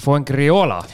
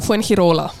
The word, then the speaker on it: Fuengirola: aga mina ütlen siis Fuengriola, aga päriselt on kuidagi teistmoodi ilmselt. – Vist päriselt on Fuengirola.